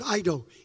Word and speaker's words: eido: That word there in verse 8 is the Greek word eido, E-I-D-O, silent E in the Greek.